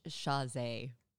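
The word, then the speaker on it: Chasez: Chavez. I fucking talked to Britney Spears at McDonald's. – I do think it's Chazé. Oh, I think we're saying his name wrong. Chazé. Uh-huh.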